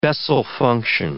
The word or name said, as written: Bessel function